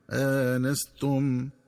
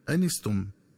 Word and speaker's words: آنستم: ينبغي التنبه للنطق الصحيح للفظ آنستم، فيقرأ بهمزة مفتوحة بعدها ألف مع فتح النون هكذا آنستم، ومن الخطأ قراءته بحذف الألف وكسر النون هكذا أنستم.